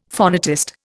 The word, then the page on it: phonetist: Indian